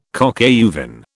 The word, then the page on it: coq au vin: British English